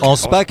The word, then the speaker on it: Anspach: Gnoni, voilà, dessinateur, et Monsieur Rodolphe, scénariste pour l'album Pump, paru aux éditions Anspach, je crois que c'est Anspach. – Anspach, sinon il va se fâcher. – Ça prononce avec le comme mais nous on roulait le R, Anspach, on faisait un truc très germanique en fait. À tort, sans doute.